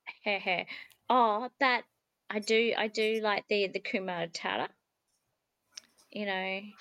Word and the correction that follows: Hakuna Matata: Hakuna Matata. – Hakuna Matata.